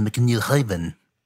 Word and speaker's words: McNiven: You said his name wrong. It's actually McNiven.